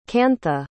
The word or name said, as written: kantha